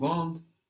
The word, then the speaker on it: vendent: Ils nous vendent la voiture lundi.